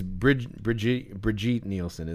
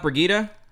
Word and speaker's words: Brigitte Nielsen: First, I think it's Brigitte Nielsen, isn't it? – It Brigitte Nielsen? It's not Brigida? – Yeah, I believe so. Okay. But I've been saying it wrong all these years.